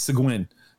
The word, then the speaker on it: Seguin: Shout out to Davila's Barbecue in Seguin. Oh, wait. I asked the lady how to tell me how to say it. It's Seguin.